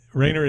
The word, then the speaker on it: Roehner: Rayner is spelled R-O-E-H-N-E-R, and Dan Ryan.